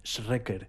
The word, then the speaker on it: Schreker: Se escribe Schreker, Schreker.